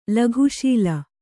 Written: ಲಘುಶೀಲ

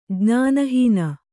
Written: ಜ್ಞಾನಹೀನ

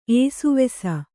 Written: ಏಸುವೆಸ

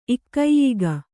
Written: ಇಕ್ಕೈಯಿಗ

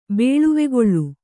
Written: ಬೇಳುವೆಗೊಳ್

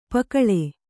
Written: ಪುಕ್ಕಲಿ

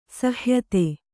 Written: ಸಹ್ಯತೆ

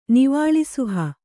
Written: ನಿವಾಳಿಸುಹ

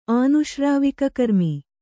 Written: ಆನುಶ್ರಾವಿಕಕರ್ಮಿ

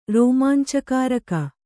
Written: ರೋಮಾಂಚಕಾರಕ

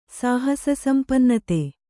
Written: ಸಾಹಸಸಂಪನ್ನತೆ

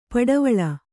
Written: ಪಡವಳಿ

